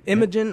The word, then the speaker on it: Imogen: Imogen, did I say that right? – Imogen, yeah. I think we'll have to ask her. – Yeah, Imogen Poots, welcome to the show. – Thank you very much. How are you doing? – Well, we are great. Did we pronounce it right, Imogen? – Yeah, you did.